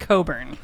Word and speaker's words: Cockburn: Anyway, her real last name, I'm going to spell it for you. C-O-C-K-B-U-R-N. – Cockburn? I could see why she changed it. – That could also be Coburn. – It is pronounced Coburn.